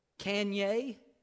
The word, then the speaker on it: Kanye: And I was told it was pronounced Kanye. Kanye.